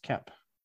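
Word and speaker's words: Koepp: The writer for the prior two films, David, I think it's pronounced Kep. – Yeah, David Kep.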